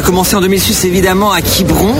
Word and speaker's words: Quiberon: Alors, Les Trois Fromages, c'est un groupe de Quiberon, je le dis bien. – Quiberon.